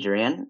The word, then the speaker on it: Jourian: So my name's TJ Jurian.